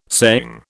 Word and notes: saying: British English

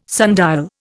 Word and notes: sundial: Indian

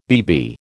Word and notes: BB: American English

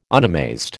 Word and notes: unamazed: American English

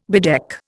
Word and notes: bedeck: Indian

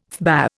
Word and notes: FBA: Indian

